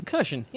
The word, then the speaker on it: Kershon: It's Kershon, yeah. – Yeah, I know, but you never know. – It's said the way it's spelled, sort of, but not quite.